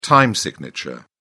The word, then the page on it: time signature: British English